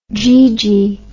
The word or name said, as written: jeegee